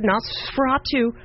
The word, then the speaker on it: Nosferatu: She's Johnny Depp's daughter, and her acting credits include Nosferatu.